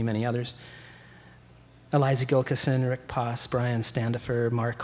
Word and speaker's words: Gilkyson: a, a, some incredible players, well-known players, Tony Gilson. Um, I'm pronouncing that correctly. Guitar. – Gilkyson, yeah. – Gilkyson, yeah. Uh,